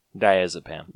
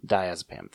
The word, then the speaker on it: Diazepam: Diazepam. Diazepam thing.